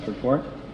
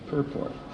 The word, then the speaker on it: purport: Srila Prabhupada's purport. Excuse me, purport. Accents on the first syllable.